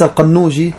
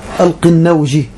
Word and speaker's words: القنوجي: هنا هناك كتاب قطف الثمر في بيان عقيدة أهل الأثر للعلامة صديق حسن خان القنوجي، والقنوجي النطق صحيح هكذا القنوجي وليس القنوجي، القنوجي.